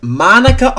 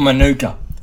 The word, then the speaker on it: Manuka: Is it being played at Monica Oval? – Or Manuka? – Okay. – So the groundskeeper at Manuka Oval. – Alright. – He pronounced it. He said, yeah, we'll be down at Manuka Oval for the...